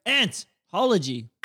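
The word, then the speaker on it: ANThology: Is that how we're pronouncing it still? – Antology. – Antology.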